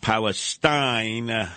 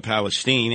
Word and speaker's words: Palestine: Yeah, well, you'll never be able to get back to living your lives the way they were in East Palestine. That's the way I would pronounce it instead of Palestine.